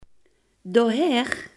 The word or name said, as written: daarheen